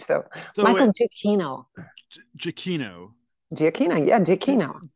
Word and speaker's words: Giacchino: Well, the director, I'm going to get the name right, Michael Giacchino.